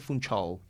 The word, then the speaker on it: Funchal: Ahí se pronuncia Funchal.